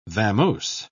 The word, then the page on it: vamose: British English